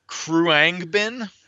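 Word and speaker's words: Khruangbin: I'm going to go with the pronunciation Kruangbin. – Oh, man. – It is spelled Kruangbin.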